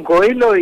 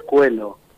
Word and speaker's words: Coelho: Eh, y se, pronuncia en castellano Coelho y en portugués Coelho.